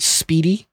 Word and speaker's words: SPDI: The SPDI charter, pronounced speedy, speedy, breaks all kinds of records in banking that is hard to immediately consume.